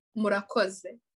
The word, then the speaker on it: murakoze: Murakoze. – Murakoze? – Yeah. – Yeah? – Yeah, you said it right. Yeah, murakoze. It's like Z-E at the end.